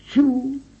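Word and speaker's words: ĉu: Repito, la C con acento circunflejo se pronuncia igual que nuestra letra che de la palabra muchacho, chocolate. La palabra chu, la C con circunflejo y la U, chu, equivale al signo de principio de interrogación.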